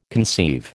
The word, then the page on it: conceive: American English